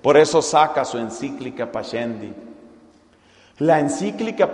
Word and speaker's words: Pascendi: Se escribe P-A-S-C-E-N-D-I. Pascendi. Pascendi, la encíclica de San Pío X.